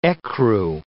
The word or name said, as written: ecru